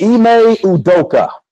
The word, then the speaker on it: Ime Udoka: His name is Ime Udoka. There you go. Okay, I won't be saying that all the time, but Ime. I hope I'm saying his name right. Ime Udoka.